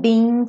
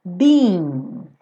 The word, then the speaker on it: being: Então, I don't know if I'm being foolish. Being. Tem que ter esse som, being, being, tá? Porque senão fica be ou been, né? Então, being. São como duas sílabas.